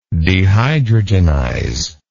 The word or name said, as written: dehydrogenizer